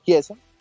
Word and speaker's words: Chiesa: a Federico Chiesa, disculpe, se dice así, ¿no? Sí.